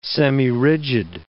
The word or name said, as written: semirigid